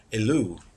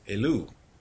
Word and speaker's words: Elul: And that's how you say it in Hebrew, Elul, Elul.